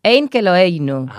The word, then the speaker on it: Ein Keloeinu: Sí, sí, lo has dicho bien. Ein Keloeinu. ¿Qué significa? – gracias. Bueno, significa no hay nadie como nuestro Dios. – Es un poco complicado muchas veces hablar en hebreo y pronunciar en hebreo, pero lo habías dicho bien.